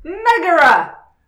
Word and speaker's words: Megara: I got Megara. That's how it's pronounced, right? Because mm-hmm. – I always want to say Megara when I see it written now. – Megara. – It throws me off.